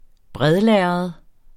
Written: bredlærred

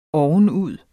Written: ovenud

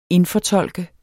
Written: INDFORTOLKET

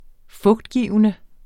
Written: fugtgivende

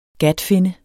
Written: gatfinne

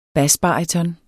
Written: basbaryton

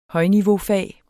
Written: højniveaufag

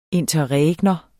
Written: interregnum

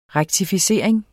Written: rektificering

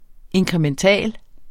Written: inkrementel

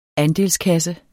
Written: andelskasse

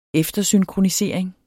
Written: eftersynkronisering

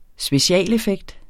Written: specialeffekt

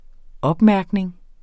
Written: opmærkning

